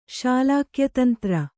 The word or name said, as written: ಶಾಲಾಕ್ಯತಂತ್ರ